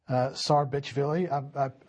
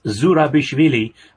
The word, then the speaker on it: Zurabishvili: So this was Stoltenberg visiting Georgia, meeting the Georgian president, Salome Zurabishvili. I deliberately pronounced that incorrectly. How should it be pronounced? – It's Zurabishvili, but I'm okay with Zurabishvili